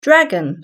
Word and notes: dragon: British English

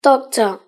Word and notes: doctor: British English

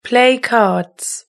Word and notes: play cards: British English

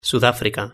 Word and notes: sudafrica: Spain Spanish

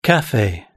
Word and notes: cafe: British English

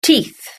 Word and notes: teeth: British English

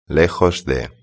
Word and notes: lejos de: Spain Spanish

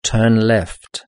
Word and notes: turn left: British English

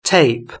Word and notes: tape: British English

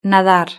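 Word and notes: nadar: Spanish (Spain)

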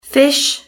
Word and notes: fish: British English

